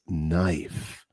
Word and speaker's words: knife: So, por ejemplo, la palabra knife empieza con la letra K, pero no se pronuncia. Es silencioso. So, es knife.